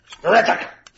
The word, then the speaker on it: Grichuk: I don't know how to say his name. – Randa. No, you say just the same pronunciation, just grittier. – Grichuk! – Yeah, that's it.